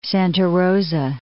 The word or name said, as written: Santa Rosa